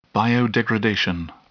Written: biodegradation

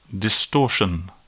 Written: distortion

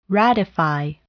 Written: ratify

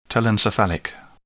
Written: telencephalic

